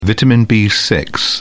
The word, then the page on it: vitamin b6: British English